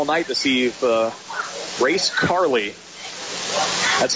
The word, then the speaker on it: Carle: So the last name is spelled C-A-R-L-E, but it's pronounced Carley.